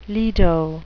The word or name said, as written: lido